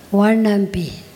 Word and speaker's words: Wanampi: Did I pronounce correctly? Wanampi? – No, Wanampi.